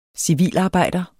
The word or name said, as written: civilarbejder